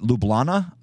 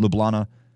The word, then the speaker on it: Ljubljana: It's an LJ. I don't know how you put that together. LJUB. Is it Ljubljana? Something like that, maybe. I'm guessing the J is silent. Ljubljana. It's a beautiful city.